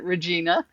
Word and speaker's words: Regina: Most of your listeners are familiar with how to spell Regina. R-E-G-I-N-A. That is my first name. Only here in the States, we pronounce it Regina.